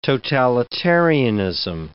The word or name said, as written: totalitarianism